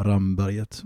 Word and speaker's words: Ramberget: Så, så är det ju med kort vokal det uttalas. Så att även enligt de uttalsregler som finns då så bör det vara Ramberget.